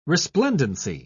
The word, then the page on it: resplendency: British English